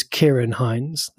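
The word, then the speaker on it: Ciarán Hinds: Oh, by the way, speaking of which, Mr. Fox from Ireland confirmed the correct pronunciation is Kieran Hines, not Syrian Hines, as I butchered it last week.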